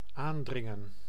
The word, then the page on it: insister: France; [ɛ̃.sis.te]